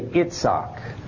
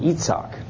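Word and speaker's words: Yitzhak: Everybody else in the world except the English speakers, say Itzhak. The I comes out like a short E or a long E, Itzhak sometimes, or I, short I, Itzhak. So the core of the word is the S and then the hard C or a K.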